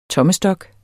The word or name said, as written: tommestok